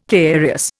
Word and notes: carious: Indian

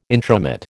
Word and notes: intromit: American English

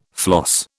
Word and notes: floss: British English

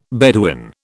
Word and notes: Bedouin: British English

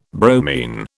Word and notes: bromine: British English

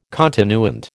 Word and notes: continuant: American English

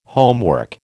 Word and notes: homework: American English

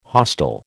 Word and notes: hostel: American English

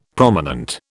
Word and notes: prominent: British English

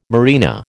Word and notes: marina: American English

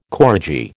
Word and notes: koradji: American English

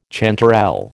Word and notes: chanterelle: American English

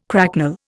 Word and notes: cracknel: Indian

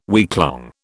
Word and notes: weeklong: British English

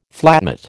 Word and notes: flatmate: American English

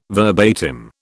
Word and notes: verbatim: British English

